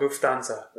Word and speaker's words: Lufthansa: It's just like it spelled, Lufthansa.